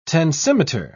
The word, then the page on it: tensimeter: British English